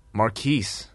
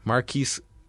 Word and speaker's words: Marquis: M-A-R-Q-U-I-S. – Marquise. – Marquise. I think it was a silent S, if I recall.